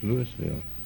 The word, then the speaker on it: Louisville: Or how would you say that? – It's Louisville.